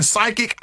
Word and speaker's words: psychic: I know you're so you're right, because when we see psychic, it's spelled P-S-Y, right? – I couldn't tell you. – Yes, yeah. This, yep. – So the P is silent.